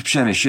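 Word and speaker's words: Przemyśl: The Great Siege of Przemysl, yeah.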